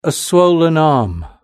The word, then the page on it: a swollen arm: British English